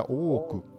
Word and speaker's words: Ooku: Se escribe O-O-K-U, Se pronuncia o k Ajá, tiene dos O.